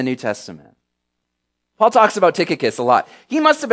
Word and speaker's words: Tychicus: Yes, Tychicus, if you were wondering how to pronounce that.